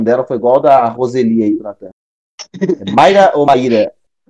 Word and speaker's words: Roseli: Com gols de Roseli ou Roseli, acho que depende, né, da pronúncia que a mãe dele. – Mano, é Roseli, Com gols de... É Roseli, é Roseli.